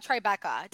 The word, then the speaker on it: Tribeca: But then Chantel, they talk about Tribeca. Did I say that right? – Yep.